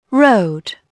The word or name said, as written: road